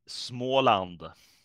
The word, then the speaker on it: Småland: Småland.